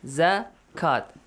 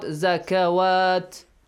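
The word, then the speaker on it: zakat (زكاة): So it will be pronounced as zakat, not zakawat.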